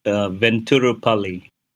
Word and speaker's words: Venturapalli: Well, the pronunciation is Venturapalli. – Okay. – It's a soft T, but, you know, that's too much detail.